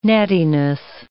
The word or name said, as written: nattiness